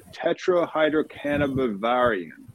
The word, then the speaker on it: tetrahydrocannabivarin: But it's a tetrahydrocannabivarin that's how that's pronounced, but it's THCV.